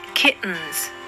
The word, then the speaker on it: kittens: そして kittens、子猫ですね、の T もグロッタル T と呼ばれる特殊な発音をします。